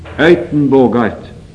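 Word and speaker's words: Uytenbogaert: så skrives det oftest med UY, Uitenbogart, skal visst nok være bortimot riktig uttale på hollandsk.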